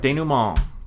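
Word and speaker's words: dénouement: Denouement. – That's right. Denouement.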